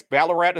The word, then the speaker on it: Ballarat: I think that's Ballarat. I think it's how it's pronounced.